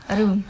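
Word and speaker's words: room: روم از حرفوی از دبل او در داخل این جمله استفاده شده است ولی او تلفظ می شود اوی دراز روم.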